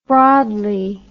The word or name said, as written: broadly